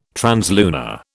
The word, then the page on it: translunar: British English